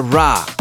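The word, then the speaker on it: Rahh: Prácticamente ahí en la mezcla con Joel Corry y la vocalista Ra. Suena un poco raro cuando digo Ra, pero es que es la pronunciación. Se escribe R-A-H-H y es una vocalista que me encanta.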